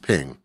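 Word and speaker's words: PNG: And, there's just a lot of file formats out there, and Ping, which, by the way, in the specification tells you how to pronounce it. It's pronounced Ping.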